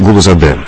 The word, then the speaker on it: Гулузаде: Галузаде, если не ошибаюсь, да? Правильно сказал? – Да, вы говорили именно с московским, я бы так сказал, акцентом. Вафан Ильич Гулузаде, правильно? – Ради бога, извините, пожалуйста, не хотел обидеть.